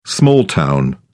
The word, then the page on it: small town: British English